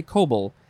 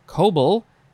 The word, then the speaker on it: COBOL: I think you would just say cobble. C-O-B-O-L.